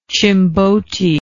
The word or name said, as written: chimbote